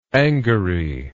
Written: angary

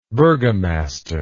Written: burgomastership

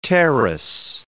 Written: terrace

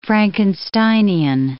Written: Frankensteinian

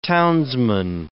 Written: townsman

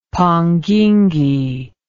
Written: panguingue

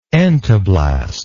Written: entoblastic

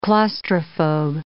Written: claustrophobe

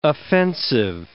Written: offensive

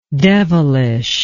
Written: devilishness

